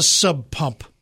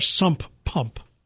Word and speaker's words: sump pump: What's a sub pump or a sump pump? – Sump pump. – Sump pump. – A sump pump. – It's. I'm telling you, it gets mispronounced. The first way I said it, that's sub pump.